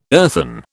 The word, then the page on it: earthen: British English